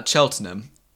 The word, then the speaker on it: Cheltenham: Cheltenham. Cheltenham. – Cheltenham? Cheltenham? I've never even heard. – We just say Cheltenham. Words. We'll just make it into like two syllables, but it's like Chelt and Ham.